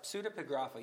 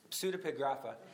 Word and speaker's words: Pseudepigrapha: How do you pronounce that? Crazy big Greek word. Pseudepigrapha. Pseudepigrapha. You do pronounce the P and S in Greek. Pseudepigrapha. So you have Apocrypha and Pseudepigrapha.